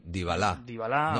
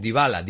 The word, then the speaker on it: Dybala: Arriba, pues, tiene a Diabalá, un argentino. – Dybala. – No, es que es Dybala, me parece que se pronuncia.